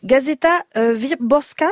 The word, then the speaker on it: Gazeta Wyborcza: J'ai découvert la une d'un quotidien libéral, alors excusez-moi pour la prononciation, Gazeta Wyborcza, c'est ça? On dit ça comme ça? – C'est à peu près ça.